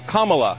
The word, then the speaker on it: Kamala: Her name is pronounced comma, like the punctuation mark, Kamala.